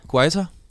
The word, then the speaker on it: Guaita: Vi sendte Guai... Guaita. Guaita, siger Campo Christian på udtalen.